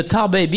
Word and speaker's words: Tarbais: And the tarbé bean, which is a specialty. – How do you spell that? – T-A-R-B-A-I-S.